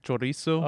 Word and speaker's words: chorizo: How do you say it? – Chorizo.